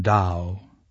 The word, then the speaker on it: Tao: That's spelled T-A-O, but pronounced Tao, D-A-O.